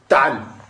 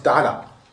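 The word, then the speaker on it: Ta'al (تعال): Donc quand tu parles à un homme, pour dire de venir, tu dis Ta'al. Akada. Ta'al. À la base, c'est une fatha, mais quand tu t'arrêtes, tu ne prononces pas. Mais à la base, c'est Ta'ala. Mais quand tu t'arrêtes, tu dis Ta'al.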